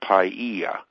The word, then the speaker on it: Paia: But if he's off for a few days, he then heads out to Maui, where he has a home near a town called Paia, and they pronounce it Paia.